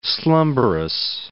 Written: slumberous

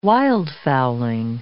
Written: wildfowling

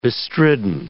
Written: bestridden

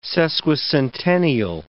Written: sesquicentennial

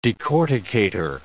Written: decorticator

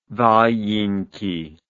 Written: valenki

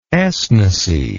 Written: esnecy

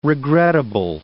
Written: regrettable

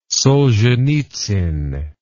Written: solzhenitsyn

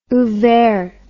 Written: ouvert

